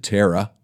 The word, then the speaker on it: Terri: then it's L-E-N-N-I-E. My wife's name, we pronounce it Tara. Her mother spelled it wrong. T-E-R-R-I.